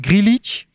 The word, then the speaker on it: Grealish: Want Grealish, nou ja, in de transfer Jack? – Update... Wat? – Jack? – Oh, sorry. Hoe spreek je het uit? – Nee, Grealish. Je zegt het goed inderdaad.